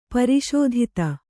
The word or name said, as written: ಪರಿಸ್ಥಿತಿ